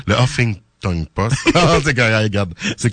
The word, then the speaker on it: Huffington: David Chabot écrit aussi pour le Huffington Post, si je le dis bien. – Il y a pas de T dans le milieu, c'est Huffington.